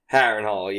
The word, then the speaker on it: Harrenhal: Harrenhal, yeah.